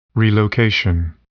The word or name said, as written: relocation